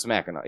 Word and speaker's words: Mackinac: Like probably there's 492 people that live in Mackinac City. – Yeah. – On Mackinac Island. – Yeah, okay. Yeah, that's Mackinac.